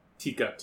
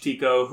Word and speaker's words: Tico: Tika. – Tika? – I believe it's Tico. It's spelled T-I-C-O.